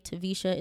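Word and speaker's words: Taversha: The name is spelled T as in Tom, A, V as in Victor, E, R, S as in Sam, H, A, Tavisha. It's spelled Tavisha.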